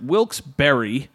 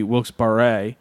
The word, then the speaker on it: Wilkes-Barre: I asked someone, this is the Google answer, how is Wilkes-Barre pronounced? I asked someone who lived there, and she said, Wilkes-Barre, but added Barre is a French name. It should be Wilkes-Barre. I've lived in Wilkes-Barre for 30 plus years, and Wilkes-Barre is another very common pronunciation.